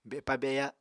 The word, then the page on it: bepa-beya: Apatani